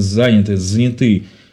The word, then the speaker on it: заняты: Вроде нет времени, но цель эфира, да, помочь вам, папам, которые заняты, как правильно ударение по-русски, заняты или заняты? – Заняты работой, а некоторые двумя-тремя работами.